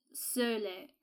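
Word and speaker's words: Sölle: He shared with us a chapter of a book by feminist liberation and theologian Dorothy Sole. – Oh, she's German, so it's Sole. I can't pronounce the umlaut.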